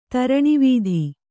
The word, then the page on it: ದೂರಾನ್ವಿತ: kannada